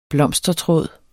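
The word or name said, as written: blomstertråd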